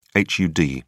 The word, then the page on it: hud: British English